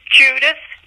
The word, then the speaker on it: Judith: Yes, it's Judith, J-U-D-I-T-H. The last name is Felsoy, and it's F-A-L-Z as in zoo.